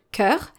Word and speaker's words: cœur: So in our case here, in our second case with the word cœur, the ligature is followed by U, which is a vowel. Therefore, we are going to pronounce C like the letter K, que.